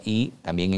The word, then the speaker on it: yi: Y se escribe YI y se pronuncia I también en chino y quiere decir la intención.